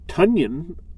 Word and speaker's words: Tonyan: Robert Tonyan, that's how you say it, of the Packers is number 9.